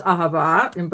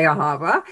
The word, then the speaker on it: Ahava: I would not be at Ahava, and I love how you pronounce it, because that's the right pronunciation. Oh. It is Ahava, but you know, Americans say Ahava.